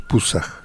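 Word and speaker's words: Pusaq: El 8 es Pusaj. – Pusaj. – Sí, escrito al final con K.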